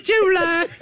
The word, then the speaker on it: July: Hey, just get the second syllable out. That's all that... – Calm down, Miss Hattie. – You know what? Yes, it can be July if you don't spend so much time on the first syllable.